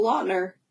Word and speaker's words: Lautner: So I met, I don't know how to pronounce his last name, Taylor Lautner? – Yeah, you got it. – Okay, because I feel like everyone pronounces it differently. – No, Lautner.